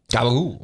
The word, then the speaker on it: capicola: Somebody posted an article on the fan page just now how capicola became gabagool, the Italian New Jersey accent explained.